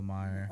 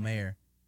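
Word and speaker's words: Maher: No, it's not Bill Meyer. – Bill Nye? – The science guy? – Bill. – Is it Bill Mayer? – Oh, that's how you say it.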